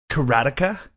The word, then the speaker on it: Karateka: It's pronounced Karateka.